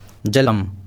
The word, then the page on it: जलम्: /ˈdʒələm/